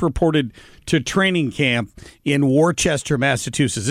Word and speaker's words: Worcester: reminding me that it's pronounced Worcester.